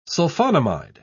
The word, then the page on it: sulphonamide: British English